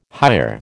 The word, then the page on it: hire: American English